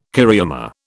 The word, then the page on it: cariama: British English